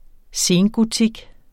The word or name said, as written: sengotik